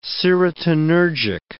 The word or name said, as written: serotonergic